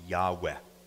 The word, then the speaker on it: Yahweh: In Hebrew, it's pronounced Yahweh.